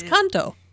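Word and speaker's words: Cantó: Where'd you get that extra syllable? – I don't know. – It's canto. – Yeah. But like, I'm seeing a I in between the. – It's got a accent.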